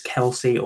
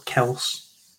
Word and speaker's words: Kelce: Moving on to the tight end position, Travis Kelce or Kels, whichever one you want to pronounce it as.